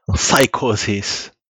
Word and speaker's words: Psychosis: Alors, dis-moi, comment faut le prononcer Faut le prononcer à l'anglaise ou à la française euh, à l'anglaise, à l'américaine, mais euh, comme euh, je suis français, ça va donner un truc affreux. Psychosis.